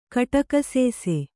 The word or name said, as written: ಕಟಕಸೇಸೆ